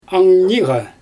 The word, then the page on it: angniiga: Xyzyl